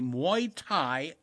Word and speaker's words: Muay Thai: Oh, I don't know how to pronounce this. M-U-A-Y. I'll say Muay Thai gym, punching people and pads.